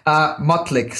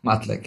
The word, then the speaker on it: Motlik: Oh, and how do you pronounce the last name? – Motlik. So, okay. – Motlik, yeah. – I didn't get close, I don't think. – Pretty much. I think you went Maltic. – Dang it. Oh, man. – But I heard that often, so that's a very typical, like, mispronunciation.